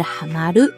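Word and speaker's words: 嵌まる: 那读音呢，都是哈马鲁。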